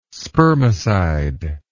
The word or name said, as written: spermicide